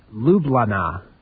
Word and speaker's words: Ljubljana: It's pronounced Ljubljana, but it's not spelled like that at all.